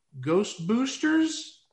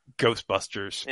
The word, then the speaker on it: Ghostbusters: And obviously, in 84, Dan Aykroyd had a pretty decent movie, Ghost Boosters? I think I got my pronunciation wrong there. Ghostbusters? – Ghostbusters.